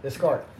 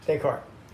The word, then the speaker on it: Descartes: Descartes. – Was that? – Descartes.